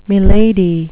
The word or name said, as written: milady